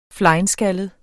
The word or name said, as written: flenskaldet